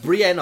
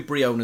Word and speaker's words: Brionne: And that would be the evolution for Popplio, which is Brionne. – Brienne, I pronounce it as, but could be Brionne as well.